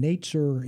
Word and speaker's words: netzer: Well, the term for the branch is the Hebrew word netzer for branch, and netzer in Hebrew sounds like the word for Nazarene.